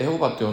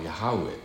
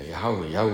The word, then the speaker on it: ヤハウェ: それ無理やり読んでエホバって読んだのがまあ、間違った読み方なんだけども、まあ、まあまかり取ってるわけですよ。エホバになってるわけですけども、エホバっていうのは本来の聖書のどこにもなくて、本来の正しい発音はヤハウェっていうことですね。ヤハウェ、ヤウェの神、